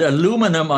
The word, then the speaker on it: aluminum: The supply chain here was the absolutely vital material of aluminium, as it's pronounced in one part of the Atlantic, and aluminum on the other part of the Atlantic,